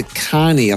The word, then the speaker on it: Kahney: The book is by Leander Kahney, I think it's pronounced, K-A-H-N-E-Y.